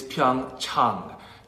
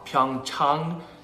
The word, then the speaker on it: Pyeongchang: The correct pronunciation is Pyeongchang. Chang, ah, ah, like when you go see your doctor, ah. Pyeongchang, Pyeongchang, Pyeongchang.